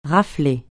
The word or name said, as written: rafler